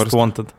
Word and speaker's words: Most Wanted: Такое ощущение, что вы неправильно произносите название. – Давайте я вам Most Wanted. – Most Wanted. – Ну да. Ну мы же говорим, как в России.